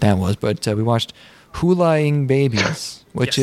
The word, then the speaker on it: Hulaing: Okay, because I was like Hulaing, and then they start saying it, and you're like, oh, Hulaing is pronounced Hulaing, which is the same pronunciation as flying.